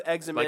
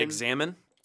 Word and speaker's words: examen: And so the prayer of examen. – Like examine? – Examine. Yeah. Okay. Yeah. And it's E-X-A-M-E-N is the way it's spelled in that sense.